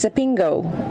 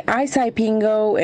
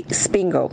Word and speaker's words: Isipingo: Most visitors, well, unfortunately, they cannot pronounce the name as Sepingo. And they say Isipingo and a whole array of other mispronunciations. So we locals, to make it easy on them, we simply say, just say Spingo. You're coming to Spingo.